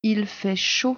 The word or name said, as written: il fait chaud